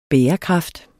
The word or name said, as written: bærekraft